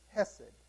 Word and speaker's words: chesed: And the word is hesed. C-H-E-S-E-D. Hesed. The sea is silent.